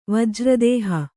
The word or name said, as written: ವಜ್ರದೇಹಿ